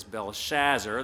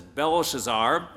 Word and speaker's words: Belshazzar: That can be rendered into English very commonly as Belshazzar, the pronunciation I used in the scripture. But actually, the other pronunciation, which is equally acceptable, is Belshazzar, and that's the one I'll be going with in the sermon, since it's actually closer to the sound of the original name.